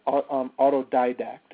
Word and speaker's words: autodidact: So how do you spell that word, diadact? I'm typing stuff up in the chat room. – Oh, sorry. It's autodidact. It's just A-U-T-O-D-I-A. – Mm-hmm. – D-A-C-T.